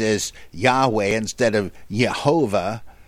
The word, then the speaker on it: Yahweh: Supposedly, it would be best to pronounce it Yahweh, as I often do.